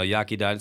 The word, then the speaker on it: Iechyd da: So, you know, Yakida, it's spelled really awkward. Like it starts with an I and, you know, a bunch of different language rules going on in the Welsh language.